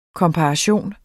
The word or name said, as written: komparation